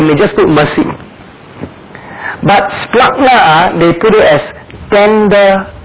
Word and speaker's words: splankna: The word splagna is spelled S-P-L-A-N-K-N-A, splagna, the N is silent.